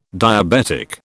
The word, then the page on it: diabetic: British English